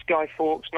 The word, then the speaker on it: Guy Fawkes: Is that the same thing as that bonfire night and that Guy Fawkes night? Are we pronouncing that right? – It is, yeah. It's bonfire night. It's Guy Fawkes night.